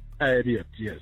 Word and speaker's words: Abiot: Have I pronounced your name correctly? – A-B-I-O-T. – Yes, Abiyat. – Abiyat, yes.